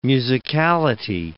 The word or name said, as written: musicality